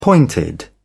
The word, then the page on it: pointed: British English